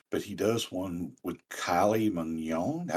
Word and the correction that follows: Kylie Minogue: I don't know how you say her. – Kylie Minogue, I think. – Minogue, that's it.